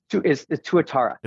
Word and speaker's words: Tuatara: It's Tuatara.